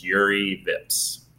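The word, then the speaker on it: Jüri Vips: I think it's you might correct me on the pronunciation, but it looks like it's Yuri Vips.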